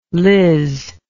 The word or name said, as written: Liz